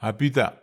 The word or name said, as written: apita